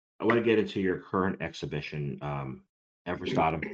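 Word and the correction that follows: Esrevatem: you pronounce it Ezravadum. – Ezravadum. Which is metaverse backwards, right? – Yes, it is.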